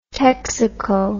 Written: texaco

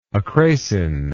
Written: acrasin

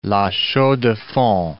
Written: La Chaux-de-Fonds

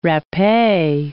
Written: rappee

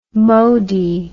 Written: modi

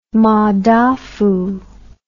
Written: madafu

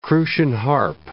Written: crucian carp

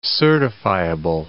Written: certifiable

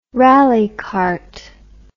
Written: rallicart

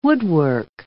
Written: woodwork

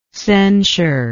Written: censureless

